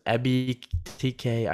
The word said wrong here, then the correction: Ebiketie: I can't Arnold Ebiketie.